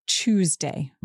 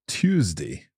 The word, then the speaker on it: Tuesday: I say Tuesday with a ch sound at the beginning. – And I think normally I say Tuesday with a t sound at the beginning.